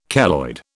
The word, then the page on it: keloid: British English